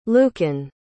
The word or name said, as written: lucan